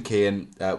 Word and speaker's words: Keoghan: I said it's by Jim Keehan, which is spelled K-E-O-G-H-A-N.